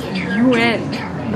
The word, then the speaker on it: yuan: The correct pronunciation is something like yuan. But that's just to get started. The first U part is pronounced with the mouth forward, like the German letter U with an umlaut, which I think is U or something. Second N part is really somewhere between N and An.